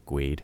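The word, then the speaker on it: GUID: which can we all just agree is pronounced Gweed? Because I love saying Gweed.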